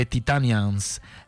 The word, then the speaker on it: Titanians: Yes, je vous laisse avec une dernière chanson de ce groupe de Titanians, comme on dit en espagnol, et comme eux-mêmes prononcent, de Titanians.